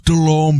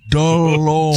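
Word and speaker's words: Delhomme: It's pronounced DeLome, Benny. DeLome.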